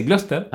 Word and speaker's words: Gloucester: Sí, estas palabras inglesas son bastante complicadas de pronunciar, ya que Gloucester en realidad se dice Gloucester, y la ciudad de Gloucestershire se dice Gloucestershire.